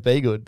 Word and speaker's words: Bedggood: Domonic Bedggood. I would go with Bedggood. That's what I'd go with.